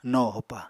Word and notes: no hopa: Apatani

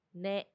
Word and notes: ne: Macuiltianguis

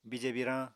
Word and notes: bije biirañ: Apatani

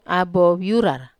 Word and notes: abo viurar: Wamesa

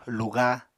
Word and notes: luga: Apatani

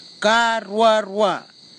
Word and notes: karuarua: Kwamera

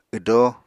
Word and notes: wdo: Apatani

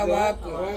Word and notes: awaku: Ansus

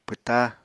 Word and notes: pwta: Apatani

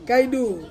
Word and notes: kaidu: Ansus

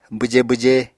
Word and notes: bwjé bwjé: apatani

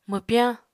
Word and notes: mwpyañ: Apatani

